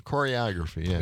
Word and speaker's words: choreography: Choreography. Well, how do you say that? – Choreography? – Choreography, yeah. – There you go.